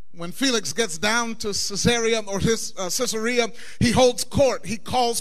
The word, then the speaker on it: Caesarea: And long story short, Paul ends up being transferred to Caesarea or Caesarea. You can pronounce it like you want to. The actual Greek pronunciation is Caesarea.